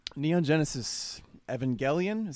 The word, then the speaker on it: Neon Genesis Evangelion: Neon Genesis Evangelion.